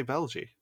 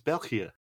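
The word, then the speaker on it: België: You cannot pronounce Belgie properly, because you always say Belgie. It's Belgia. – Oh, is it because it has the two dots above the E? – Yeah, and also the G letter in Dutch pronunciation is G.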